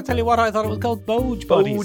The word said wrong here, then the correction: Bog Bodies: Boge Bodies. That's one of classic mispronunciation bits. – It is. It's pronounced Bog Bodies.